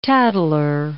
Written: tattler

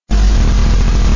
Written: damaged

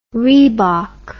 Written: reebok

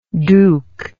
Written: dook

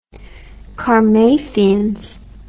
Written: karmathians

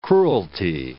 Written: cruelty

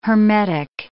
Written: hermetic

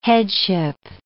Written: headship

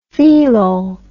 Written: theelol

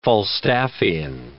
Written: Falstaffian

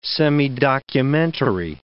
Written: semidocumentary

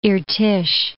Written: Irtysh

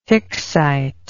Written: Hicksite

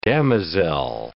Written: damosel

